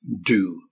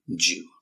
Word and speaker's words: due: Okay, it's got a sound of the J sound, and you will hear it, as I said, from Americans who pronounce it due. Okay, but try from an English, British English point of view, due.